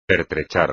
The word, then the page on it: pertrechar: espagnol